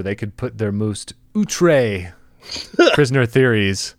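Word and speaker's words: outré: That's outre. – Outre. – You have to pronounce the accent that I always have to look up how to type.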